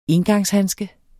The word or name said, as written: engangshandske